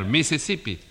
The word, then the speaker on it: Mississippi: La I no se pronuncia ahí. – Sí. – Mira, a ver, ahí lo dice, que hay un río que se llama igual, Maisai Pai Pai. – No, señor, Mississippi.